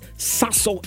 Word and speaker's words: Sasso: When they want to give you a different brand, tell them that Sasso is the brand I need to go for. It is spelled S-A-S-S-O. S-A-S-S-O. It's Waka Sasso.